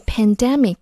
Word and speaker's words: pandemic: 下一个词是 pandemic。它也是最近很热门的一个词汇，要注意它的发音，重音在 d 前面，读作 pandemic。